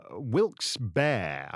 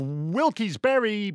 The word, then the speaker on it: Wilkes-Barre: We're going to be in Reading, Pennsylvania, and Rochester, New York, and Syracuse, New York, and then Wilkes-Barre. I think that's how you pronounce it. Pennsylvania, because it's like it's spelt like Barry, Vermont, but it's not said like Barry, Vermont.